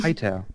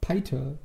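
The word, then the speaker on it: Piter: Pitter, hm? dann gibt es Pitter, der. Oder ich weiß nicht, wie der ausgesprochen wird, aber. – Äh, nicht... Peiter. – Peiter, okay, Peiter.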